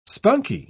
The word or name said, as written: spunkie